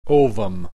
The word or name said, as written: ovum